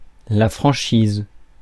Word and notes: excess: American English

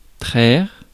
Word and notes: traire: France; [tʁɛʁ]

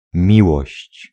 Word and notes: kärlek: /ˈɕɛːrˌleːk/

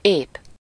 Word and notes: sûr: France; [syʁ]

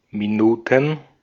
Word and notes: Minuten: /miˈnuːtn̩/

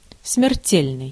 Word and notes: deadly: /ˈdɛdli/

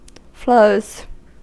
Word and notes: flows: American English; IPA: /ˈfloʊz/

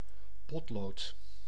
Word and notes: potlood: /ˈpɔt.loːt/

